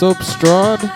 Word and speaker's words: SBSTRD: This is, I think it's pronounced Substrad, S-B-S-T-R-D, with Haoma.